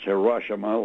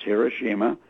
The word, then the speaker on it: Hiroshima: Not to be pronounced Hiroshima, like the lamestream press is trying to change it. Mm-hmm. It's Hiroshima. And also what they had on board this submarine was 560 kilos of uranium.